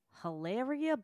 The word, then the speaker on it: Hilaria: Hilaria Baldwin with Deanna Ching. – She would cut her throat if she heard her name being pronounced like that. – It is Hilaria.